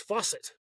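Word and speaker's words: FOSET: Next part of this is Fawcett. Let's discuss. Yes. Did I say that right? – Yeah. Yeah. So it's F-O-S-E-T.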